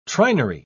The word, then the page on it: trinary: British English